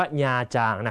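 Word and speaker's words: Nha Trang: คำนี้ต้องอ่านให้ถูกนะฮะอ่านว่าญาจางนะ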